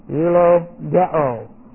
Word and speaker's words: eulogeo: Eulogeo. E-U-L-O-G-E, long O.